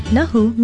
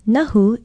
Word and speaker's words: Nahu: Nahu, meaning to bite, is our Hawaiian word for today. Nahu is spelled N-A-H-U,